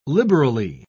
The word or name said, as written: liberally